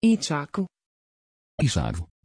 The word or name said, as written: isaque